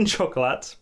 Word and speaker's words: Chocolat: Don't pronounce the T. – Chocolat. – Chocolat. – Chocolat. – Chocolat. He's given us a scene from Chocolat. – He's given us a scene from Chocolat because that's how it's spelt. Like, the French must just see T's and go, nah.